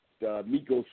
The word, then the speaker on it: Migos: And then later on, Migos was doing the, am I pronouncing that right? Amigos? – Migos. – Migos.